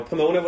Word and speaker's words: Pomona: And there was a second one called the Festival of Pomona. I hope I'm saying that right. – You are saying Pomona right.